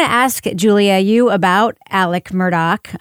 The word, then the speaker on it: Murdaugh: And we should mention, I think, for listeners who maybe have seen his name in headlines, it is pronounced Alec Murdoch and not Alex Murdaugh, even though that's how it's spelled.